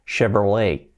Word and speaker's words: Chevrolet: Pues fijaos que la pronunciación viene más del francés, aunque es marca americana, es Chevrolet, Chevrolet, Chevrolet, ¿vale?